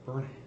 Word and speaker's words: Burnham: I like Batman Incorporated number seven, written by Grant Morrison, art by Chris Burnham, and Chris Berman. Berman. Burnham. Man, I said it right when I was saying the names. Now I can't say it right.